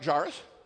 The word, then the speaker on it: Jairus: Am I pronouncing it right? Thanks, guys. I can almost see. Is it right, Jairus? Okay, thank you.